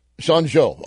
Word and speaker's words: Zhengzhou: So one of the reports that came out, you'll have to pronounce a name for me. It's Zhengzhou City. Is that or it's close? Zhengzhou, something like that? – Zhengzhou. – I was close. No, I wasn't.